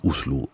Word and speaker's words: Oslo: Oslo spricht man Oslo aus, also dies fürs Protokoll.